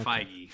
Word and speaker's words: Feige: And, you know, being buddies with Kevin Feige. – How do you pronounce it? It is Feige.